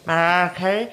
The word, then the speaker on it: Mariah Carey: Mariah Carey.